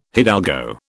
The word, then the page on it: hidalgo: British English